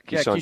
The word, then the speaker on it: Keyshawn: Is it Keyshawn? Is that how you say his name? – Yeah, Keyshawn George.